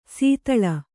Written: ಸೀತಳ

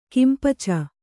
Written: ಕಿಂಪಚ

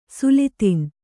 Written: ಸುಲ್ತಾನ್

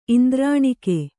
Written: ಇಂದ್ರನಗ

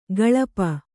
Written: ಗಳಪ

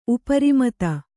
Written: ಉಪರಿಮತ

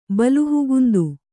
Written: ಬಲುಹುಗುಂದು

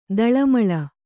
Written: ತಾಳಮೇಳ

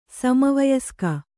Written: ಸಮವಯಸ್ಕೆ

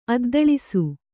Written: ಅದ್ದಳಿಸು

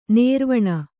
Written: ನಿರ್ವಾಣಿ

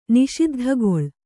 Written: ನಿಷಿದ್ಧಗೊಳ್

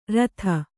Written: ಋತಿ

